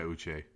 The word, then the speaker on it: Uche: The Patriots defense, watching them last night, their front seven with Matthew Judon and that Josh, I don't even know how to pronounce his last, Uche? – Yeah, Uche.